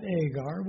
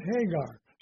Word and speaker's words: Hagar: So the translator just missed the rough breathing, which requires it to be not Agar, but Hagar.